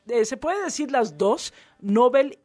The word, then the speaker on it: Nobel: Es que por aquí me dicen, y lo agradezco, me dice por acá el Jorge Luna, me dice la palabra Nobel, es aguda, es sin acento, con sílaba tónica, Bel, Nobel.